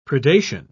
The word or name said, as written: predation